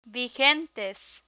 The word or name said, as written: vigentes